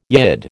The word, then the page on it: Yid: American English